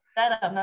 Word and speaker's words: Dedham: Dedham.